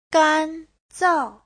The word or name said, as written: gan1zao4